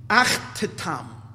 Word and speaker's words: Achtetam: Therefore, the word becomes, the way it's pronounced in Kabbalistic terminology, Achtetam.